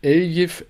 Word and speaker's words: Eljif: Eljif Elmas.